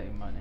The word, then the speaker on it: Imani: So your senior season in high school, what, roughly January 2021, your brother, Imani, is that how you say his name? – Yeah, Imani.